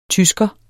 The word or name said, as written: tysker